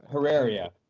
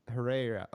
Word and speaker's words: Herreria: Uh, I- am gonna probably butcher your last name, Herrera. Is that how you say it? I close enough. No, um, Herreria. Herreria.